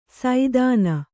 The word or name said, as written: ಸೈಧಾನ